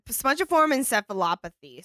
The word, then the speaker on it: spongiform encephalopathy: Spongiform encephalopathy.